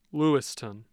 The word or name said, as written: Lewistown